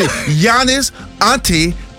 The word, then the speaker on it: Giannis Antetokounmpo: Yeah, did I say it right? – Did he say it? – Let me see. – Giannis Antetokounmpo. Tacumpo. – There you go. – Come on now. – Yeah, you got it.